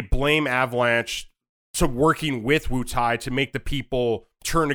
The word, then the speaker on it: Wutai: Wutai, yeah.